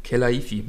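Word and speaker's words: Khelaifi: Nasser Al khalifi Kelaifi. Ja, stimmt. Kelaifi. Ja, ja, ja, so spricht man es aus, richtig.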